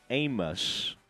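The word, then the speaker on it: Abmas: Max Amos, it's A-B-M-A-S, but I believe the B is silent, and he's a great player for Oral Roberts.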